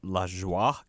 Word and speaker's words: Lajoie: Most of them are written by a guy named um, Jon Lajoie or La, La Joie because because it's, it's, spelled like the French for. – He's for Canadian. Joy. He's from Quebec, so I bet oh, it is right. La Joie.